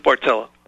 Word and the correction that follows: Barletta: or if I pronounce Lou Barletta. – Barletta. Barletta, correct. Yeah.